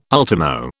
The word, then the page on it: ultimo: British English